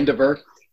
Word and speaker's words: Vandiver: Please fill in the blanks and teach me again how to say your last name. I was worried about mispronouncing it, and I think I did. So welcome, James. – You did very well. It's okay. Vandiver. – Vandiver. – Right.